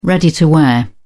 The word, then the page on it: ready-to-wear: British English